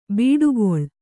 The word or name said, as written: ಬಿಡುಕುಳ